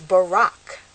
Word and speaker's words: Barak: is Barak.